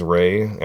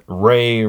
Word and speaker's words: Rey: And we're going to do a giveaway because Rey rhymes with giveaway.